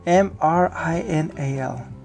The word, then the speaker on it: Mrinal: said Casper Labs co founder and CEO Marinal. How do you say that? Mer. M R I N A L. Mrinal, Mrinal, Mrinal Manohar, Mrinal Manohar.